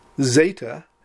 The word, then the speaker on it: zeta: Zeta, that's got a DZ sound, as in ads. Try to get that DZ in there.